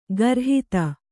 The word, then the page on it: ಖುರಾಹತಿ: khurāhati